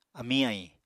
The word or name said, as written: amiñ-ahiñ